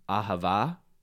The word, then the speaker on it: ahava: In Hebrew, it's pronounced ahava, A-H-A-V-A-H. That's like the transliterated.